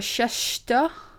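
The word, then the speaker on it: Csejte: Die in kasteel, nu moet ik dit goed gaan uitspreken, want dit is vrij lastig. Chester. Chester? Ja, Chester. Het is C-S-E. JTE. ik geloof dat je het goed uitspreekt. ik heb geen idee.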